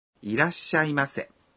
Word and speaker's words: いらっしゃいませ: いらっしゃいませ를 발음할 때는 라 다음에 한 박자 숨을 멈춥니다. – いらっしゃいませ. 이라시아이마세.